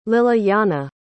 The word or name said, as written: lilyana